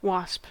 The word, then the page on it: wasp: American English